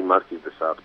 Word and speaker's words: Marquis de Sade: Za nami Sens Schizofrenika i tak się spytałem słuchaczy, czy ktoś pamięta grupę Marquis de Sade, czy de Sade, de Sade, powinno się wymawiać nazwę? Jak to? – Myśmy mówili Marquis de Sade po prostu.